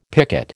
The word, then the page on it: picket: American English